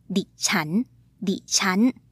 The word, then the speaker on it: ดิฉัน: She used the word ดิฉัน which is pronounced colloquially as ดิฉัน di-chan.